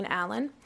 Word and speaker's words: Allen: Chairman Usher, members of the committee, my name is Reverend Laura Jean Allen. Allen is spelled A-L-L-E-N.